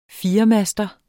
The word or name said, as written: firemaster